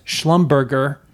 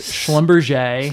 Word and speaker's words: Schlumberger: Then when I got into the business, I used to pronounce this company as Schlumberger. But now I know better. It's Schlumberger.